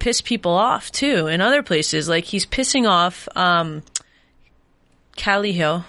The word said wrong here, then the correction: Caliendo: How do you say it again? – Caliendo. – Caliendo. Officer Caliendo,